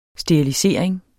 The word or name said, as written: sterilisering